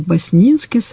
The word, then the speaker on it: Баснинский: ну, некоторое ударение делает на второй слог, Баснинский сад,